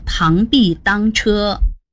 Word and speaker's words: 螳臂当车: 螳臂当车的当是当然的当，但是在这句成语中的当指的是阻挡。现代汉语词典第7版说，螳臂当车也说螳臂挡车，阻挡的挡有个提手旁。 – 但是如果看到的成语用的字是当，还是得读作螳臂当车。